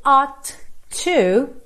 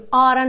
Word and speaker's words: ought to: Aqui a gente tem esse ought to, que é um verbo modal, que normalmente a gente vai pronunciar ora, you oughta know, ao invés de you ought to know, you ought to know. Mesmo que eu fale ought to, percebe que eu tenho um som só de T, ought to, eu não falo ought to, ought to.